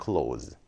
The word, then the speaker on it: clothes: clothes라는 것은 z 발음 끝나는데 발음적으로 조금 구분이 되어져야 되겠죠. cloth. cloth. 이게 복수가 되면 clothes. 이런 식으로써 s 발음으로 끝나고요. 옷은 그냥 close 이런 식으로써 아예 다른 단어를 생각하시면 돼요.